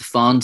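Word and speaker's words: Pfand: So I think it's pronounced the Farn system, where you have to pay a deposit on non-single use glass and plastic bottles and also the crates that they come in.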